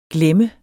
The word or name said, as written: glemme